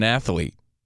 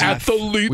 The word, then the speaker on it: athlete: And think of the shelf life of an athlete. – Stop saying it's not three syllables. – It's three syllables. Where I'm from, Union, New Jersey. – Athlete. – We say athlete.